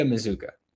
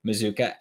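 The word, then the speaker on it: Mazzuca: And like I said, we're sticking on the offensive side of the ball here for a few minutes to talk about Micah Mazzucca, which is how it's pronounced. It's spelled Mazkua, but it's pronounced Mazzucca.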